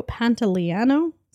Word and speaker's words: Pantoliano: It stars Jennifer Tilly as Violet, Gina Gershon as Corky, Joe Pantoliano, is that how you say it? – That's it.